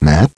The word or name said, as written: Matt